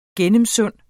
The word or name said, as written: gennemsund